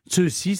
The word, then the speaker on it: Cixi: Ceci, ça s'écrit Cixi, hein, pour si vous demandez. – C'est la, oui, ceci, c'est la transcription actuelle.